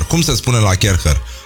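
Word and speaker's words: Kärcher: Bun, deci în concluzie avem acest concurs de la Kerher, care și-a pus practic toată încrederea în mâinile noastre. Încrederea în primul rând că o să reușim să pronunțăm numele Kerher.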